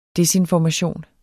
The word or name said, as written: desinformation